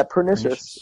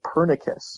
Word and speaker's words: Pernicious: So, Pernicious, I'm saying it right, right? Pernicious. – Yeah, yeah, Pernicious. Pernicious. Definitely. A lot of people say Pernicus. Pernicus. It is Pernicious.